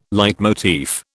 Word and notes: leitmotif: British English

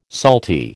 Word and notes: salty: American English

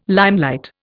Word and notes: limelight: Indian

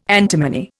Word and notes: antimony: Indian

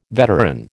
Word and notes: veteran: American English

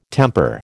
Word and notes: temper: American English

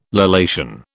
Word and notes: lallation: British English

